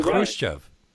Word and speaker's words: Khrushchev: If it's a K and an H together, it's a H. It should be Khrushchev.